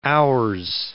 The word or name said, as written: ours